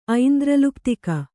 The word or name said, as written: ಐಂದ್ರಲುಪ್ತಿಕ